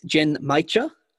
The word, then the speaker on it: Genmaicha: So its name is, and pronunciation caveat here again, is Gen Maicha. So not matcha, but Genmaicha.